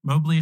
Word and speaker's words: Mobley: Mobley is spelled M-O-B-L-E-Y.